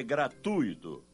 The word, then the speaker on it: gratuito: Como você pronuncia gratuito ou gratuito? O certo é gratuito, com U tônico. A sílaba tônica recai no U e não no I, e não leva acento gráfico.